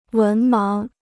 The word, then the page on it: 文盲: wén máng